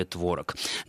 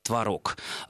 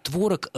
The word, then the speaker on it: творог: но меня удивляет ваше замечание о том, что раньше говорили творог. Дело в том, что вообще-то исконным, правильным и так называемой старшей нормой, ударением соответствующим будет творог, от глагола творожить.